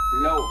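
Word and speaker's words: Law: Low. – Low. L-A-W. L-A-W. Oui, c'est la sous l'eau. La sous l'eau. Ça s'écrit L-A-W. L-A-W.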